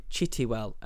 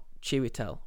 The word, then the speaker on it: Chiwetel: Now, the reason I'm doing this is because I mispronounce his name slightly, and I call him Chitiwell and not Chiwetel, which is his actual name.